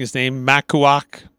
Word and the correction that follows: Maluak: Maluak? – Uh, yeah, Maluak.